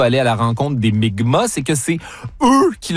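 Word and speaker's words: Mi'kmaq: Où Jacques Cartier et son équipage vont être accueillis par des membres de la nation Micmac, qui se prononce en fait Mi'kma, dans ce qui sera le premier contact.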